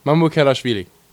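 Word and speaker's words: Mamukelashvili: The pronunciation is Mamukelavishvili. – Mamukelavishvili.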